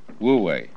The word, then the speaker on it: Wu Wei: And then this is pronounced, this one is Wu, and this one is Wei, Wu Wei.